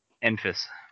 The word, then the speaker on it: Enfys: Enfys. – Enfys Enfys Nest.